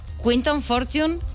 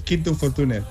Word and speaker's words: Quinton Fortune: Quinton Fortune, ¿lo hemos pronunciado bien? – Sí. Bueno, aunque normalmente en España es Quinto Fortune, pero es igual.